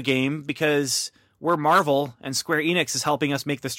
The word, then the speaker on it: Square Enix: Square Enix, is that how you say it? – Square Enix, yeah.